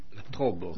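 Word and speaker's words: La Tròba: Et tu as publié, donc, si tu veux bien le montrer à la caméra, ce tout gros livre, La Trouba, c'est comme ça qu'on dit ? – La Trobo. – La Trobo, voilà. Oui, le A se prononce haut.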